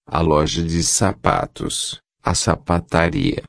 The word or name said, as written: loja de sapatos sapataria